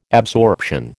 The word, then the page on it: absorption: American English